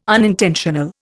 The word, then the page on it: unintentional: Indian